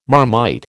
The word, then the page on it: Marmite: American English